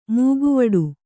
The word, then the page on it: ಮೊಕವಾಡ: mokavāḍa